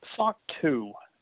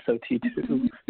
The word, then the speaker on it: SOC 2: So SOC 2 is how you just pronounced that. I was saying SOT2 before.